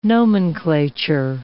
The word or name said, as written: nomenclature